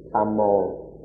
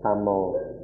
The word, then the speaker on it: tamo: In Pali, it's simply tamo, tamo.